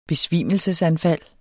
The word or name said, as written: besvimelsesanfald